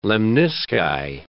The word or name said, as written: lemnisci